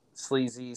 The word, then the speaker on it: Sleezy: My name is Eric Clark, E-R-I-C-C-L-A-R-K, a.k.a. Sleezy, S-L-E-E-Z-Y-E. And the reason I spell that, it's just like Sleezy. Sometimes people spell S-L-E-A-Z-Y. So it is Sleezy with two E's.